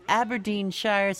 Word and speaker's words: Aberdeenshire: We've got Annie on the line from Aberdeenshire, Scotland.